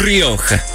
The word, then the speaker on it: Rioja: Oh, Rioja.